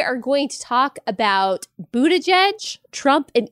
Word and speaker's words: Buttigieg: he specifically told the audience how to say his last name, and it is Pete Buttigieg.